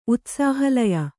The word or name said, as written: ಉತ್ಸಾಹಲಯ